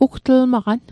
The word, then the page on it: ugtlmaqan: Mi'gmaq